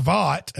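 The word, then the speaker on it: Vogt: Okay, so anyway, Mr. Vaught, and it's V-O-G-T, forgive me if I pronounce it wrong. I'm just going to act like the G is silent. But Steven Vaught came to the plate,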